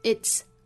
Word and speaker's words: it's: It's only 之间有一个连读。首先我们要知道 it's 这个单词的发音。 It 后面加一个 s 读成 it's。 It's 我们知道它是 it is 的缩约。缩约之后呢尾部的这个 ts 读成 ts。 ts。很像一次两次次的那个声母哈